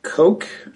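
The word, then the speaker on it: Cocke: I'd like to acknowledge that the mayor recommended appointment, and the planning and the city council appointed Mr. Paul Koch, I believe is how he pronounces his name. It's C-O-C-K-E.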